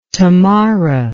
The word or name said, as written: Tamara